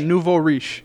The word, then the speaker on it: nouveau riche: Yeah, nouveau riche.